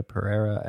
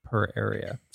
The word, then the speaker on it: Pereira: Haywood, Keke Palmer as Emerald Haywood, Brenda Pereira as Angel Tor or er, as a- Branda Pereira. Pereira, sorry, butchered that.